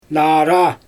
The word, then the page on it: naaraa: Xyzyl